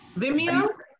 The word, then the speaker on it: Vimeo: V-I-M-E-O. – Vimeo? – Vimeo, yeah.